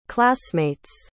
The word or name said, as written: classmates